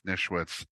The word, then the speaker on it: Nischwitz: Jeff Nischwitz, N-I-S-C-H-W-I-T-Z.